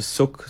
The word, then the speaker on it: Shuk: Um, no, I mean, it, so Shuk is actually a market um, in Hebrew. It's uh, in, in um, Arabic, it's a suk, so it's spelt differently.